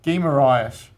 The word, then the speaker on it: Guimarães: Guimarães. I think that's how you pronounce it, I think. – That's it.